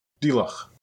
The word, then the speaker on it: Diolch: Diolch. I think that's how it's pronounced. It means thanks in Welsh.